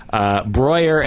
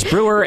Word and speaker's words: Breuer: And now I feel double dumb because I was pronouncing Breuer as Brewer and Horni as horny.